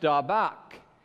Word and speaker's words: dabak: But in this moment, this hold fast or this cleave, the root word is from Hebrew, and it's pronounced dabak, and it means to cling or adhere, to catch by pursuit, to pursue hard with affection and devotion.